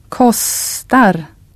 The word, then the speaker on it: kostar: Now try a long consonant sound, a long S, in the word for cost. – Kostar.